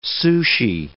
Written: sushi

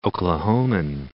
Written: Oklahoman

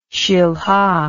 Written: shilha

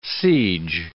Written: siege

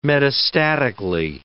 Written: metastatically

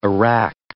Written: arak

